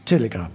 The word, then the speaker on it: Telegram: Telegram. Zo moet ik het uitspreken. – Telegram. – Een beetje op zijn... – Op zijn Russisch.